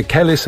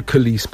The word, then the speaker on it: Kelis: Pisze się Kelis, ale mówi się Kelis podobno.